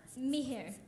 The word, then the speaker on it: Mihir: M-I-H-I-R. – How do you pronounce it? – Mihir.